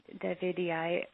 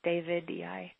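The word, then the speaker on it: davidii: But the butterfly bush, Buddleia Davidiae, or it should actually be pronounced David-E-I.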